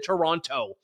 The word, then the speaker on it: Toronto: I did the Toronto. You're supposed to say Toronto. I never pronounce the second T.